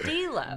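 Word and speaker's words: D'Lo: It's just D-Lo. – D-Lo, Mississippi.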